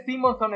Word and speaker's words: Simonson: Espérame, Walter Simonson escribió la mitad de Avengers, por lo menos me hacen bullying por pronunciar mal la palabra. – Perdón, La mitad, William, Simonson. – Sí, con los dos sons.